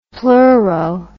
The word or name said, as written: pleuro